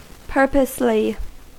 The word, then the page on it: purposely: American English